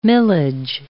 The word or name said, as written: millage